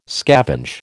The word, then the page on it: scavenge: American English